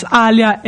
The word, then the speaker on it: Alya: So it's Alya, A-L-Y-A, and then my surname, E-L-O-U-I-S-S-I.